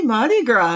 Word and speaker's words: Mardi Gras: I'm probably not doing this properly, but happy Mardi Gras. – Happy Mardi Gras, yes.